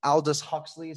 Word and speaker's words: Aldous Huxley: So, John, you chose a quote from, I believe you pronounce it's Aldous Huxley is how you pronounce the name.